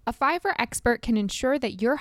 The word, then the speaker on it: Fiverr: So the first resource that I love is Fiverr, and that's spelled F-I-V-E-R-R.